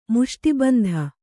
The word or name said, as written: ಮುಷ್ಟಿಬಂಧ